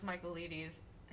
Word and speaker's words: Michaelides: The book is, you might know what I'm going to say, The Maidens by Alex Michaelides. That is how you pronounce his last name. The Maidens by Alex Michaelides.